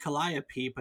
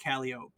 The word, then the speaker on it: calliope: The Greek muse by the same name is produced calliope, but the instrument was usually pronounced calliope by the people who played it. A 19th century magazine attempted to settle dispute by publishing this rhyme. Proud folk stare after me, call me Calliope. Tooting joy, tooting hope, I am the Calliope.